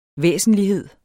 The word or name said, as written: væsentlighed